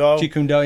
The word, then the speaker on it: Jeet Kune Do: If I'm saying that right, Jeet Kune Do. – Jeet Kune Do, yeah.